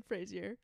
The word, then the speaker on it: Fraser: In preparation for The Mummy, Brendan Fraser Did I say that right? – Fraser.